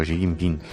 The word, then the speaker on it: Xi Jinping: Que no es cualquiera el que viene, es Xi Jinping, que en fin, que bien lo pronuncias, por cierto, Xi Jinping.